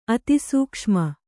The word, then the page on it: ಅತಿಸೂಕ್ಷ್ಮ: Kannada; atisūkṣma